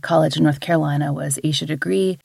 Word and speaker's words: Aisha Degree: People sometimes call her Asha Degree. I've heard her family call her Aisha Degree, though, on the news a good bit. So that's the pronunciation that I tend to use.